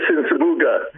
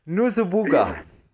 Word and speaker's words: Nsubuga: Did I get it right? It's really a hard pronunciation, Chris. Please help me out. Chris Nusubuga. Chris Nusubuga. That's right.